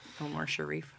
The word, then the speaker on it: Omar Sharif: Umar Sharif, who I like to say his name a lot. – Omar Sharif.